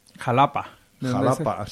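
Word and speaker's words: Xalapa: En Veracruz, en el estado de Veracruz, está Xatapa, me parece. – Jalapa. – Se dice con X, sí. Jalapa, que se escribe con X.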